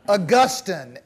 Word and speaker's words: Augustine: Augustine. A-U-G-U-S-T-I-N-E. Augustine. Sometimes pronounced Augustine. Augustine will make you sound more intelligent. If you pronounce it Augustine, yeah.